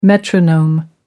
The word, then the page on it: metronome: American English